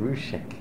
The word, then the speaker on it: Rieussec: R I E U S S E C. Exactly. How do you pronounce it? Rieussec. It's funny, my friends pronounce it Ruchek.